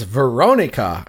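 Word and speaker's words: Verónica: But now we're going to talk about what we're here to talk about, which is Veronica. And I'm going to put the accent on the O because there's the accent on the O. And that's the way the characters pronounce the character's name in this film.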